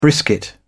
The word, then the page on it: brisket: British English